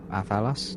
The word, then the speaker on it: athelas: Okay, you find 2 doses of Athalas. – Athalas? – Yep, Athalas. 2 doses of Athalas. – Spell it, please. – A-T-H-E-L-A-S.